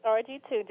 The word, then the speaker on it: RG2: Was it RG the second or RG2? – It's RG2, just like you said it the first time.